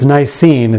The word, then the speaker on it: Nicene: Actually, it's Nicene. It's two syllables, not three.